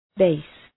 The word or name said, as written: base